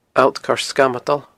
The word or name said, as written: Allt Coire Sgamadail